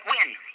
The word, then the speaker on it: Nguyen: For our listeners, it's spelled N-G-U-Y-E-N and pronounced Win.